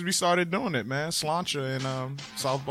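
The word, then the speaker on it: Sláinte: Slancha.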